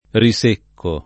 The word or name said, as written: risecco